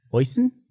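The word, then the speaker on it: Oisín: How do you spell, how do you pronounce O-I-S-I-N? Oisin? Oisin?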